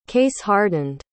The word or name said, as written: case-hardened